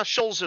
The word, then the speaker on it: shoals: I always feel like I'm saying it wrong, but no, shoals is right. Just as if it was S H O L E S.